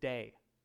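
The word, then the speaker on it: day (δέ): The word is day. D-E, spelled D-E, pronounced day.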